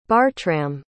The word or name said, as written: bartram